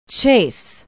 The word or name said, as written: chase